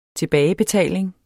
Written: TILBAGEBETALINGS

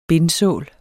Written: bindsål

